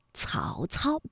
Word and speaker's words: 曹操: 曹操呢是舌尖要抵住上齿背哎去发音的。曹操，大家注意一下它们的发音位置。